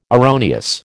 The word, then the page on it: erroneous: American English